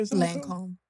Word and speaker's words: Lancôme: Am I saying that right? – Lancôme. – Yeah, same thing.